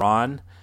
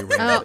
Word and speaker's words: Ran: I think it's actually pronounced Ron. So... – Ron? – Oh. – And Han debate. It was a joke. It's actually Ran.